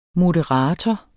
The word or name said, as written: moderator